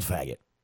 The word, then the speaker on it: Faget: It's called Faggot. It's called Faggot. It is called Faggot. It's spelled F-A-G-E-T. That is the name of the song.